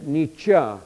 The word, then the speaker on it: Nietzsche: And moving on, now we move to, some people say Nietzsche. It is pronounced Friedrich Nietzsche, actually, the German philosopher, interpreted all human action in terms of what he called the will to power.